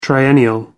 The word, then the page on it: triennial: American English